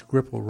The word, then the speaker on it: Skripal: Which is Skripal rhymes with script,